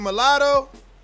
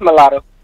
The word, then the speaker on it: Mulatto: Is it Mulatto? – Yeah, it's Mulatto.